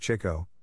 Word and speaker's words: Chico: The name should actually be pronounced Chico, which was the way Groucho had always said it, but in an early show program, the K was inadvertently dropped, and the new spelling and pronunciation, Chico, stuck.